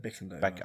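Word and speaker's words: Bicondova: Is it Bakandova or is it Bickandova? – I don't know how to say it. Bakandova? – Is Bakandova. It an A or an I? – It's an I.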